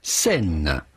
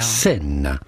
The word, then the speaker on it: Seine: In French, we would say Seine. La rivière Seine. La Seine. So it'd be fine in English or American to say Seine River.